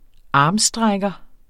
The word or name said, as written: armstrækker